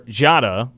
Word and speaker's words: Diarra: because he will tell you like why it pronounces it on the air, Jada, which is what Cartier Jarre will tell you is how it's actually pronounced, but the pronunciation guide says Jarre, actually on K-State's game notes if you are going to look at those.